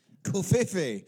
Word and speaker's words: Coffey: It's a Japanese single malt whiskey, it's 90 proof, and it's Nikka Coffee Malt, or Coffee Malt, I'm not sure how to pronounce it, C-O-F-F-E-Y. – Coffee.